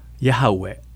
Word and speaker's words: ヤハウェ: の名前はレビ記に最初に登場しますエホバとは私はあるという意味です神様の名前は 4 つのヘブル語のアルファベットヨッド、ヘイ、バブ、ヘイから成り立っています。それをヤハウェと読みます。